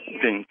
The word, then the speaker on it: Vince: Vince, Florida. – Welcome to the call. Well, I'm sorry, you're from Florida. What's your name? – Vince, V-I-N-C-E. – Vin, welcome, Vince.